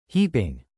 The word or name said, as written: heaping